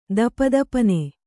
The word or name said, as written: ದಪದಪನೆ